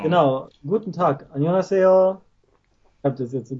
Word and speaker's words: Annyeonghaseyo: Annyeonghaseyo. Ah, okay. – Annyeonghaseyo. Und man sagt, je schneller man sagt, desto natürlicher klingt's, glaube ich. Man verschluckt dabei einige Silben, aber wenn man so Annyeonghaseyo es gibt kaum Widerstand so beim Sprechen und mhm.